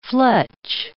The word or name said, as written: fletch